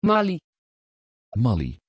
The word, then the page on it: Maylie: Dutch